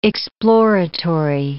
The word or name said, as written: exploratory